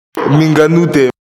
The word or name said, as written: minganote